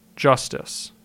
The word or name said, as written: Justice